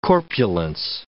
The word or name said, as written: corpulence